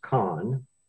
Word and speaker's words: Chan: Her name is Allison Kahn. Her last name is spelled C-H-A-N, but it's pronounced Kahn.